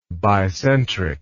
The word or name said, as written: bicentrically